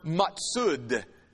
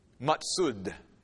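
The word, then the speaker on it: matsud (מצודה): And the Hebrew word that's used here in chapter 22, verse 4, is the Hebrew word matsud. I'll spell it for you. M-A-T-S-W-U-D. Matsud.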